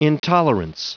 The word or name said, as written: intolerance